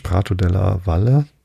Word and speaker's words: Prato della Valle: Der große Platz, Prato de, de la Valle, hätte ich beinahe Spanisch ausgesprochen, Della Valle. Nee, es ist Italienisch, Prato della Valle, hoffe ich.